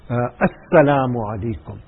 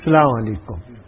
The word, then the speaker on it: assalamu alaikum: One Sudanese man told our Shaykh, Hazrat Rahmatullah Ali, that salam also, the alif lam at the beginning, assalamu alaikum, that also should be pronounced properly. It shouldn't be salamu alaikum.